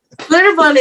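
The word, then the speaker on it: Schlitterbahn: But it's not spelt that way. – Oh. – It's spelled like it's German. – Yeah. – Oh, I know what you're talking about. Slitterbond.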